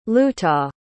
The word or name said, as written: lootah